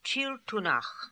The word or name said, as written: Chiltoonakh